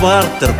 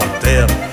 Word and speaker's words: партер: А если вы в театр придете, например, вас не пропустят в партер, пожалуйте в партер.